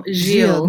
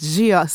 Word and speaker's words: Gilles: So, joining me on this episode, I am so excited to say is Keisha Giles. Did I pronounce that right? – No, Gilles. No, I do. It's Gilles.